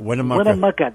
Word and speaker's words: Winnemucca: Winnemucca.